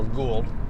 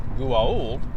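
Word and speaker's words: Goa'uld: Well, the TV show fixed that by they gave the race a name, Gu'a'uld, or Gould. I personally like the official Gu'a'uld pronunciation of Gu'a'uld.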